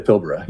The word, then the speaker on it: Pilbara: Is that how you pronounce that? – The Pilbara, yep.